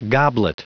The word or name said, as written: goblet